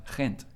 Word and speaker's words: Ghent: Ghent.